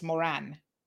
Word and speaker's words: Moran: It's Moran. M-O-R-A-N, not M-O-R-O-N.